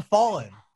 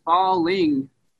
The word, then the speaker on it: Fallin': And, and, and there is a difference between the hard G and the N apostrophe, correct? – For sure, nailed it. For I'm sure, glad you noticed it. Well, see, falling ain't got no swag.